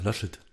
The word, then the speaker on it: Laschet: Laschet.